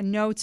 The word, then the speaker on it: Ngo: Hey, we were talking about Andy Ngo, and thanks to the texter who sent me a note saying his, Joe, if you want to just move that text back up the other way, it's pronounced No, Andy Ngo, N-O.